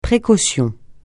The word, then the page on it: précaution: francais